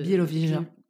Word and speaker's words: Białowieża: Alors en France, on n'en a plus beaucoup, mais par exemple, en Pologne, il y a la réserve de Białowieża.